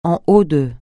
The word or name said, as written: en haut de